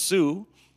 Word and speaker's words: Sault: Pronounced Sue, but spelled S-A-U-L-T.